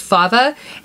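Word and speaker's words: fève: But it always has a fava in it, which is F-E-V-E, but it's pronounced fava.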